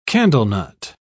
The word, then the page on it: candlenut: British English